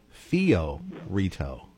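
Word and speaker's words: Fiorito: It's Ted Fio Rito. I said Flo.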